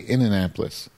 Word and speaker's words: Indianapolis: Well, that's how Indianapolis natives pronounce. They say Innanapolis.